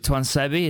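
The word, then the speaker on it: Tuanzebe: Is it Tuansebi? Is that how you say it? – Yeah.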